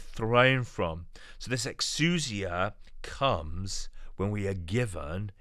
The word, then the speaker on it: exousia: Now, the word authority is the Greek word exousia, exousia. It's spelled E-X-O-U-S-I-A, exousia.